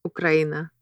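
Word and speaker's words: Ukraina (Украина): Ukraina.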